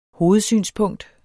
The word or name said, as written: hovedsynspunkt